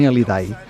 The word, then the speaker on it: Hallyday: Molts llocs se li deia Johnny Hallyday, però a França es deia la H muda també i es deia Johnny Hallyday.